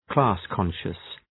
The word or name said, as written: class-conscious